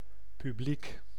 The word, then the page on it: public: /ˈpʌb.lɪk/; [ˈpʌb.lɪk]